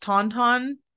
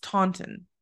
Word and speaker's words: Taunton: And the last one looks like it should be pronounced Tauntaun, like from Star Wars. – Mm-hmm. – But I think I've heard it pronounced Taunton or Taunton.